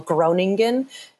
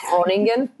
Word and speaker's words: Groningen: I was in a town as an American we would call Groningen. It's pronounced Groningen.